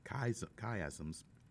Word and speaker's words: chiasm: Chiasms, and it's spelled C-H-I-A-S-M-S, C-H-I-A-S-M-S, chiasm.